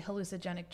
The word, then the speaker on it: hallucinogenic: And obviously hallucinogenic drugs. – Mm-hmm. – There's an extra syllable there.